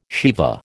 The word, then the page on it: sheva: American English